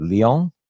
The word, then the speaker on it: Lyon: You're out in France in the Lyon area. Mm-hmm. I think I'm pronouncing that right. Lyon? – Yes. – Okay. – Lyon. That's it.